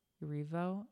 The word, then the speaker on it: Erivo: I do think that, like, it was kind of ridiculous that they had Cynthia Erivo and how do you say her freaking last name? – Erivo. – Anyway, Cynthia Erivo and Ariana Grande open the Oscars with Wicked.